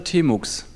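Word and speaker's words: tmux: Guten Abend, herzlich willkommen zu diesem Talk über, ja, wie spricht man es aus, T-Max oder T-Mux? – Nachdem wir in Deutschland sind, T-Mux.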